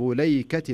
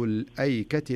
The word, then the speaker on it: الأيكة: ورش هو الذي يقرأها كذب أصحاب ليكة المرسلين، ورسمت على رواية ورش، لكنها تكتب أو تنطق في رواية حفص الألف تنطق وكأنها مكتوبة كذب أصحاب الأيكة المرسلين، زين؟